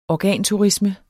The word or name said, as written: organturisme